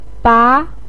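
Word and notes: ba2: tone 2

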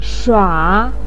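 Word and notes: shua: tone 3

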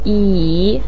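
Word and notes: yi: tone 3

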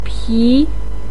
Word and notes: pi: 2

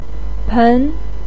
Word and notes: pen: 2

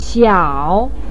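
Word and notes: xiao: tone 3

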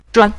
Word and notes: zhuan: zhuan5

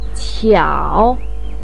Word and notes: qiao: 3

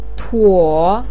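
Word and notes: tuo: tone 3

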